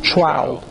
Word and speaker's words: trowel: When you go and you flatten out with a trowel. How do you pronounce that word? A trowel? – T-R-O-W-E-L. – So how do you pronounce it? Trowel? – Yeah.